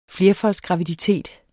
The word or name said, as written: flerfoldsgraviditet